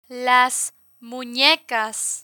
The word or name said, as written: las munecas